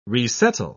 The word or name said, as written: resettle